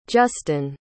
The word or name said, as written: juston